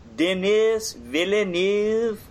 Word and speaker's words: Denis Villeneuve: So the director was Denis Villeneuve. I'm having a little trouble pronouncing this. All right, let me try again. Denis Villeneuve.